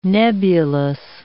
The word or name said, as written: nebulous